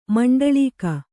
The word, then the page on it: ಮಂಡಳೀಕ: maṇḍaḷīka